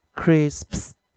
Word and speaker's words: crisps: 注意 p 是清辅音哦，所以最后结尾要念 pus pus pus，然后一样是 cr 开头，crisps crisps crisps crisps crisps crisps。